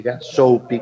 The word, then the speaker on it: Soapy: en el buscador pones Soapy que se escribe S-O-A P-Y, Soupy, como si fuera diminutivo de sopóperas,